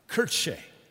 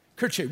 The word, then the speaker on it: Kirche: And uh, their word is, my, my, my daughter tried to uh, teach me how to say this because she's studying German, Kirche. Kirche, which phonetically sounds like our word church, which meant house of the Lord.